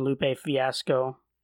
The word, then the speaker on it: Lupe Fiasco: And 15, Lupe Fiasco.